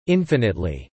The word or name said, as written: infinitely